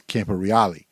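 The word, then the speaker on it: Camporeale: Because I remember once he was on The Footy Show, and they asked him, how's it pronounced? And he said, it's Campo Reale.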